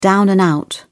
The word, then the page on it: down-and-out: British English